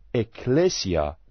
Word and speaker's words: اکلیسیا: کلمه کلیسا یا کلیسیا در زبان فارسی در اصل واژه‌ای از یونانی و تلفظ آن اکلیسیا می باشد.